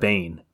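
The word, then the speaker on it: Biehn: Michael Bean. Bean is that how do you say his name? Michael Bean. Michael, I call him Michael Bain, but Bane.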